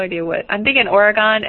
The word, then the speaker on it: Oregon: Yeah, think of it like this. Oregun.